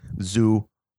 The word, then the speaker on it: Zumock: Yeah, just my name, Chad Zumok. – You better spell that for my fans. – Z-U-M-O-C-K. Two syllables, Zumok.